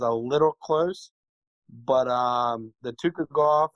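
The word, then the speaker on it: Tukhugov: Zubera Tukugov. I think I said that right. – Tukugov. – Tukugov. Yeah.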